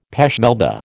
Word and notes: pêche Melba: American English

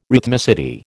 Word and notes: rhythmicity: American English

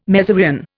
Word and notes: mezereon: Indian

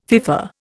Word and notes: FIFA: Indian

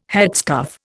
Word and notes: headscarf: Indian

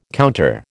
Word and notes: counter: American English

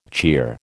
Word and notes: cheer: American English

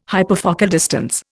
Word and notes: hyperfocal distance: Indian

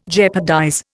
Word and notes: jeopardize: Indian

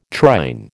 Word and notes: trine: American English

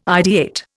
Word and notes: ideate: Indian

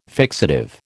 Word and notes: fixative: American English

